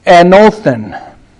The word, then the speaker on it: anothen: The Greek word translated here, from the very first, is pronounced anothen.